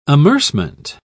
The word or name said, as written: amercement